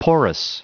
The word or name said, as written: porous